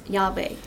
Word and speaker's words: Yahweh: And in our English Bible, we'd notice that the capitalized Lord, and actually in Hebrew, is pronounced Yahweh.